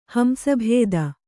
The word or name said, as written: ಹಂಸಭೇದ